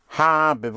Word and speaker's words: habe: Habe, without stressing it. The stress is on ha, on a. Habe.